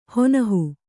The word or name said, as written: ಹೊನಹು